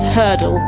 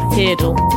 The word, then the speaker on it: Heardle: I've just also worked out that it's Hurdle, not Heardle, which is what I've been calling it all day. So thank you, Graham, for Hurdle. – Correcting me there. – Oh. – To rhyme with Wordle.